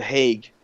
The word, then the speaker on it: Hague: Were they discovering Amsterdam or the Hog? – No. They were in the – whoa, is that how it's said? – That's how I'm saying it. – I thought it was the Hague.